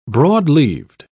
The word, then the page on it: broad-leaved: British English